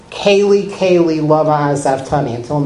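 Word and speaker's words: lama (לָמָה): The Hebrew, lamed mem hey. Pronounce that lama.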